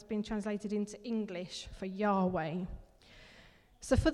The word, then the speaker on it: Yahweh: So one of the names of God that's used in the Old Testament is written down using the Hebrew consonants YHWH. So over time, in English, when we've translated it, we pronounce that Yahweh.